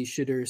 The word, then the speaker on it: Shedeur: I'm so jacked to see Shedeur Sanders. I hope I'm pronouncing that right. – Sound like you said Shedeur. – Shedeur. – Shedeur. – How do you pronounce it? – You got it right.